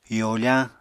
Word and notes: yofañ: apatani

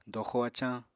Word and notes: doxo-acañ: apatani

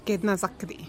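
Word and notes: ket nazakti: Teotitlán del Valle Zapotec

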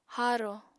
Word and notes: haaro: Apatani

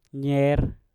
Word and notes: nyer: Mpur

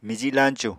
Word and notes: miji lañcu: Apatani

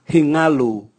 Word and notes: hingalu: Raga

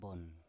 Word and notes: bon: Biak

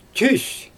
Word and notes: tys: Xyzyl

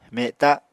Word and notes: meta: Apatani

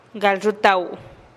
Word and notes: gal ruutau: TdVZ

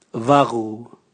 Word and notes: vagu: Raga

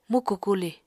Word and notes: muku kuli: Apatani

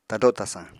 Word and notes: tado tasañ: Apatani

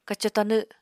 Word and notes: kaco-tanw: Apatani

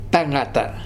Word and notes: tagata: Futuna

